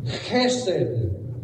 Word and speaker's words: chesed: It's the Hebrew word chesed. Chesed. They pronounce those CH words really funny, okay?